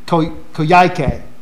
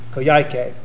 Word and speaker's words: Coyhaique: Coyhaique is how it's pronounced. Coyhaique.